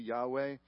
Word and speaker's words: Yahweh: Most often, you know, probably the most scholarly way of saying it is most likely Yahweh.